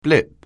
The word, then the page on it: blip: anglais